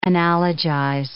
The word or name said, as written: analogize